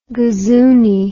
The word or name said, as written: gazooney